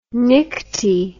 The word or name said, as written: nycti